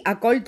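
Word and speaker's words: Acoli: Δηλαδή, Ακόλι το τονίζω εγώ, υπάρχουν μερικοί άλλοι που το τονίζουν στο α, στο επιθετό του, αλλά εγώ επιμένω να χρησιμοποιώ το Ακόλι, γιατί ξέρεις, ακούγεται λίγο περίεργα.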